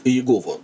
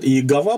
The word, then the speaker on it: Иегова: Тем более, с точки зрения исторического словоупотребления, все-таки ударение Иегов, Иегова. Есть люди, которые говорят Иегова, правда, еще, но вот Иегова это как-то уж совсем за гранью добра и зла.